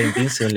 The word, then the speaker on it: Invincible: Pues, y un día le dije, y me dijeron, pero no, por eso se llama Vince Papale, que vio la película Invincible, le gusta, ¿cachai? Es Invincible como no te dais cuenta? – Pero es invincible se pronuncia.